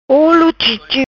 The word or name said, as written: Chipchip olo